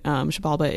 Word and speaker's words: Xibalba: and I'll spell that because it's weird. Shabalba is X-I-B-A-L-B-A.